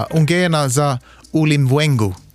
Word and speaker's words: Ungena za Ulimwengu: Kan du uttala det själv? – Nej. – Jag kan försöka. Ungena za Ulimwengu. Lät det bra? – Det lät jättebra.